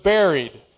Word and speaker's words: buried: It was not just that Christ died, but that he was buried, or buried, I guess you say. I always want to pronounce that with the U, but I suppose it's supposed to be pronounced as if it were an E.